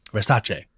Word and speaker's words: Versace: Versace.